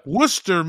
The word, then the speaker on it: Worcester: March 25th and 26th, he's at the Comedy Addict in Worcester, I think is actually Worcester. Pronounced. Yeah. – Worcester, Mass.